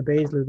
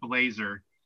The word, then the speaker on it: Baszler: Like, we'll just squash Shayna Baszler this week, and she can squash We'll let Charlotte come out and use her name wrong. Call her Shayna Blazer. I've never been more incensed in my life. Like, Jesus Shayna Christ. Blazer. Like, Shayna Blazer.